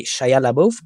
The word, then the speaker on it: Shia LaBeouf: Eh, señores actores para mí, en esta película al menos, no en toda su carrera, eh, que es Vanessa Kirby y Shia LaBeouf, que sí lo dije bien, qué bien.